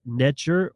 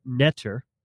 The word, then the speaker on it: Netcher: In the ancient Egyptian or Kemetian cosmology and spirituality, the concept of NTR, pronounced Netcher or Netter, holds significant importance.